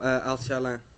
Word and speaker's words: Alshaalan: Oh, Al-Shalan. – You're going to have to spell it. – A-L-S-H-A-A-L-A-N.